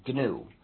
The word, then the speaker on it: GNU: But when it's the name of our system, the correct pronunciation is GNU. So please pronounce a hard G.